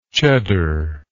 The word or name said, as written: cheddary